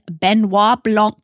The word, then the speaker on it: Benoit Blanc: Slim, let's solve a freaking mystery with our best friend, Benoit Blanc.